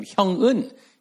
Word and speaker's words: Hyung Eun: When I went to high school in Monterey, one of my teachers couldn't pronounce my Korean name, Hyung Eun. He couldn't say Hyung or Eun. He would call me Hu. I'm not Hu. And then he called me Helwon, Helwon. I'm not a Helwon.